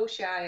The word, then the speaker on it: Wuxia: Wuxia? – Oh, Wuxia, yeah.